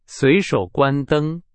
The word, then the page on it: 随手: Chinese